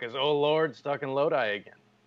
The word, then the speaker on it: Lodi: It is Lodi, L-O-D-I.